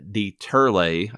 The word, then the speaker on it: Dieterle: Um, it was directed by William uh, Dieterle, I believe is how it's pronounced in German, um, or Wilhelm is what his original name was and before he immigrated.